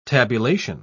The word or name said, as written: tabulation